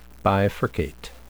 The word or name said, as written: bifurcate